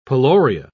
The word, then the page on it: peloria: British English